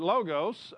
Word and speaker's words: logos: Some people pronounce this different ways. I pronounce it logos.